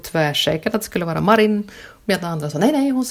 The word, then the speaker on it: Marin: Men hon är ju finländare, alltså finsk, och säger själv marin, mera, med betoningen på den första.